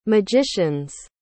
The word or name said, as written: magician's